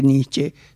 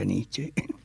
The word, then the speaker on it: Nietzsche: No vamos a hacer una cuestión de esto, pero dice que Nietzsche se pronuncia Nietzsche.